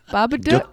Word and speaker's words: Babadook: Did I say it wrong? Babadook. – Duke.